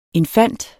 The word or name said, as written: INFANTENS